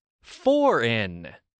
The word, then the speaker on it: foreign: The word is spelled F-O-R-E-I-G-N. What's the silent consonant? That's right, G. Okay, are you ready? Foreign. Foreign. With my accent, it has the exact same pronunciation as two English prepositions, for and in.